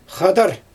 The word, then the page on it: xadar: Xyzyl